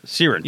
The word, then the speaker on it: syringe: Sea Wrench. – Yeah. No, not even close. – Syringe.